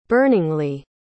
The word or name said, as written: burningly